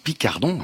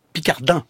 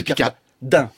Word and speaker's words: Picardan: Vous dites Picardon, hein ? – Picardin. – C'est Picardin. P-I-C-A-R-D-A-N. – Et ça se prononce Picardin.